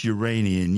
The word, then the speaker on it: Uranian: For nearly a quarter of each Uranian year, and yes, that's how you say it, the sun shines over one pole, plunging the other half of the planet into a dark 21 Earth year long winter.